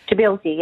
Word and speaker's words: Tbilisi: stopped in the capital Tbilisi, I don't know how you pronounce that. – Tbilisi, yeah.